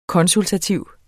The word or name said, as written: konsultativ